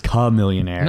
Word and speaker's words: Chamillionaire: Chamillionaire. – Hi. – Chamillionaire? – Yeah, Chamillionaire. – Yeah, it's like different. The rapper. – Yeah. – No, the rapper's Chamillionaire.